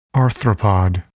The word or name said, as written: arthropod